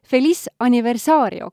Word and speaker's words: Feliz Aniversario: ma ei tea, kas ma oskangi hääldada õigesti, aga peaks olema vist Feliz Aniversario, kas nii? õige no., õige, super.